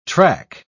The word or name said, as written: track